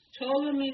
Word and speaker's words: Ptolemy: Ptolemy took Egypt. I don't know, but I always remember Ptolemy, and always that's Egypt. It's spelled with a P. P-T-O-L-E-M-Y. Ptolemy the First took Egypt.